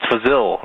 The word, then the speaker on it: Fazil: It's Fazil. Remember me? – Oh, yeah. Fazil. How you doing? Rhymes with Brazil.